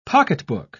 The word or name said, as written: pocketbook